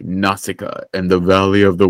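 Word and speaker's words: Nausicaä: I'm pretty sure I had a VHS that had like Nausicaa, because in my head that's how I know that it's pronounced Nausicaa.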